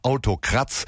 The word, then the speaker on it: Autocrats: Okay, wir sind hier bei 1 Live 4 und haben gerade Autocrats gehört, also in deutscher Aussprache Autokratz.